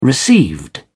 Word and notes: received: British English

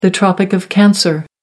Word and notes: tropic of cancer: American English

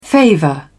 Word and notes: favour: British English

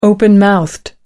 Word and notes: open mouthed: American English

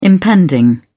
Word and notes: impending: British English

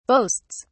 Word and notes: boasts: British English

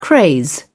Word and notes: craze: British English

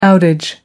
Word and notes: outage: British English

